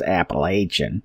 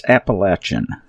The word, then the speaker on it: Appalachian: Some people call it Appalachian, and that became popular back in the 60s and 70s, when the Ivy Leaguers decided to get involved with Appalachia. And we despise that word, that pronunciation. It is so incorrect. And it's so pretentious. It's Appalachian. Sorry about your luck.